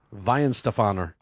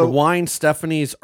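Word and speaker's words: Weihenstephaner: Can you pronounce that? – Vine Stefaner. – I tried. You missed that. – Oh, I'm sorry. – Yeah, said Wine Stephanie's So we're looking forward to Night Ranger, Marco, on August 20th.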